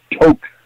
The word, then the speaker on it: Tok: You'll go through Tok. It's spelled T-O-K, really the first town you hit in Alaska.